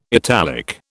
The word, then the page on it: italic: British English